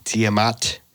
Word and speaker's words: Tiamat: Yeah, T I A M A T. Uh, Tiamat. – Yeah. They were uh, while he said they said that they were on Tiamat while they were actually hiding on Earth.